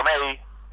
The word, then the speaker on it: Mey: Y si no, mis páginas, yo soy Mario May, M-E-Y, se escribe mi apellido artístico.